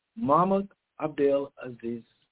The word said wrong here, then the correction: Mahmoud Abdel Aziz: I didn't say his name right. – Mahmoud Abdel Aziz. – Say it slow. – Mahmoud Abdel Aziz.